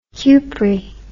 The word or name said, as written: cupri